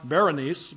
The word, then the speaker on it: Berenice: Her name's Bernice. Sometimes pronounced Berenice, but sometimes written Bernice.